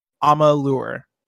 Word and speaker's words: Amalur: Quarter 1988 says Amalur is pronounced Amalur.